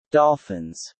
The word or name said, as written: dolphins